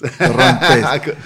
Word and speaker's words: Torrontés: I noticed you don't seem to grow any Torrontés. – Torrontés. Torrontés is a... – The listeners will know my pronunciation is appalling.